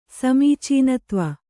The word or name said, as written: ಸಮೀಚೀನತ್ವ